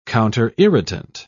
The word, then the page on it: counterirritant: British English